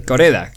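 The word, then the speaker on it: Kore-eda: El director es Hirozaku Koreeda. Casi lo digo bien, medio bien, ¿eh? – Y Koreeda, ¿no? – Koreeda.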